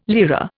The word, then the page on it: lira: Indian